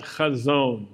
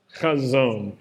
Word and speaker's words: chazon: In Hebrew, it's called chazon. Say that with me, chazon. And chazon, it's a good word. It rhymes sort of with calzone.